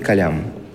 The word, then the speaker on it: калам: Хотя все профессионалы, арабисты, иронисты и так далее, знают, что это «эль» нужно произносить мягко. Это, в общем, скажем, особенность исторической транскрипции. – Понятно. – То есть, по идее, это нужно произносить «калям», как оно и, собственно, и произносится, как оно и произносится в оригинальных языках.